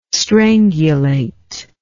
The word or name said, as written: strangulate